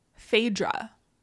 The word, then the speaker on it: Phaedra: Phaedra.